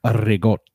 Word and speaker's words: ricotta: Do ricotta. That's fine. Add it on. – All right. – That actually or as the Italians say, rigot. – You don't roll your R's in Italian, but that's true.